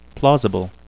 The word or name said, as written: plausible